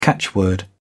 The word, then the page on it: catchword: British English